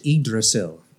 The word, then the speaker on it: Yggdrasil: So number six is pronounced Yggdrasil. Yggdrasil. Yggdrasil.